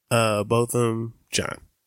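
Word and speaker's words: Botham Jean: This month or last month, we drop one about the Bottom Jean case. There's a few ways to pronounce that. How have you heard his name? – Botham John.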